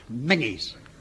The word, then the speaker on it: Menzies: Now, quite out of the blue, we've received an invitation from Major General Menzies. – Mangies. – Yeah, I beg your pardon? – It's pronounced Mangies.